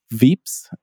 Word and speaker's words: Veeps: And I ended up at a company called Veeps, which is spelled V-E-E-P-S. So sort of like VIPs, but spelled with two E's.